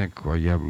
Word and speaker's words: incroyables: Donc les incroyables, alors c'est les incroyables ou muscadins et merveilleuses. Alors on ne prononce pas le R, parce que R ça veut dire révolution.